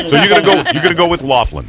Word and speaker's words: Loughlin: So, I have a couple of really important questions, but the first one is it Lori Loughlin or Lori Loughlin? – How do you pronounce it? – I say Loughlin.